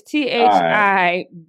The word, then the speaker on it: Thibodeaux: I believe it's Kayvon Thibodeau. Because you know I can't say last names. Let me spell it. It's Thibodeaux. – Thibodeau.